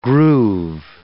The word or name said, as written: groove